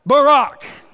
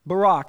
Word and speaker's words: Barak: Barak. Obama. Stop. Barak. That's exactly how it's pronounced. Barak. Barack Obama.